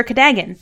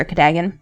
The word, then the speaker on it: Cadogan: Am I saying that correctly? Because that's the way I've always said it. – I've always said Cadogan. Sir Cadogan. – I say Cadogan as well, but Stephen Fry on our audiobook says Cadogan.